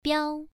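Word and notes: biao: tone 1

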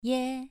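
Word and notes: ye: tone 2

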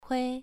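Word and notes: hui: tone 2

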